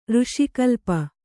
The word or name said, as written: ಋಷಿಕಲ್ಪ